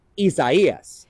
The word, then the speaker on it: Isaias: So if you didn't know how to pronounce it, this was mispronounced so much in 2020 that I just wanted to let everyone know, just as it sounds out because it's in Spanish, Isaias is going to be one that we're going to have to say a lot over this next week.